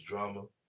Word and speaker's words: Jrama: My name is Drama. Um, Drama with a J. – Mm-hmm. – That's real specific for sure. It's like Django. Um, the D is silent.